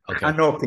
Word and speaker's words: Hanok: Like Enoch? Are we talking about Enoch? Is that how we pronounce? – Yeah, Enoch. – Okay. – Hanok in Hebrew.